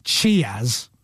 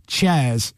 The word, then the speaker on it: cheers: And he says the universal greeting is cheers, pronounced cheers, unless you are from Cornwall, in which case it's chairs. Well done, Scott.